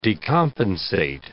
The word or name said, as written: decompensate